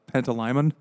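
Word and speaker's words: Pantalaimon: Because the first three quarters of the book, I was calling the demon Joe Pantaliano instead of Pantaliman. – Pantaliman. – Pantaliman is what he said.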